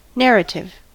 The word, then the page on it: narrative: American English